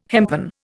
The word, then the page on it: hempen: Indian